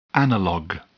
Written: analog

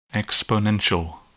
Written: exponential

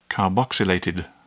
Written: carboxylated

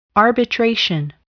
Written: arbitration

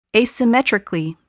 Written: asymmetrically

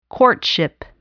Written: courtship

